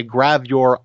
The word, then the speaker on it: gravure: She's what you call I'm pronouncing this correctly, a gravure idol, G-R-A-V-U-R-E model.